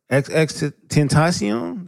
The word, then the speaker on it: XXXTentacion: When you see a man slumped in his car, XXXTentacion, that's how you say his name.